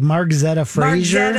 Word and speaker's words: Margzetta Frazier: remake of Taylor's version of Love Story, or of course the UCLA gymnastics Margaret, how do we say her name again? – I was like Margzetta Frazier.